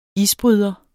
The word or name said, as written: isbryder